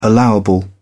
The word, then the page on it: allowable: British English